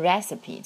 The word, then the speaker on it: recipe: 首先 recipe 这个单词我们要记住它的拼写和发音， PE 在结尾的时候我们发的是长音的 E，在美语当中啊。